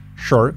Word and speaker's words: sure: Fijaros la pronunciación. Sure. Sure. Es una SH. Cuando tenemos una S y una U, normalmente se genera ese SH. Sure. Sure. Y con la R parece una O prácticamente.